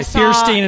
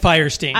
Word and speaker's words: Fierstein: I pronounce it both ways, by the way. Fierstein and Fierstein.